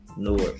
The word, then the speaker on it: Newark: Newark. But they call that shit Newark. Like, they don't even put a fucking another syllable with that motherfucker.